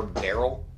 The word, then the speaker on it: Beryl: You're going to name the living star Beryl? – Beryl, yeah. – Beryl or Merrill? – Beryl. – Beryl. – B-E-R-Y-L.